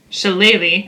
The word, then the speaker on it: Shillelagh: I would like to cast Shillelagh, which is Shillelagh. – Shillelagh. – That's right. I promise that's how you say it.